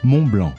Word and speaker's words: Mont Blanc: Mont Blanc.